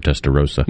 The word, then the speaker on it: Testarossa: You know how to pronounce it? – I've always heard Testarossa. – Me too.